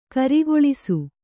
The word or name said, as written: ಕರಿಗೊಳಿಸು